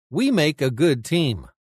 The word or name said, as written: We make a good team